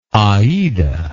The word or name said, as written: Aida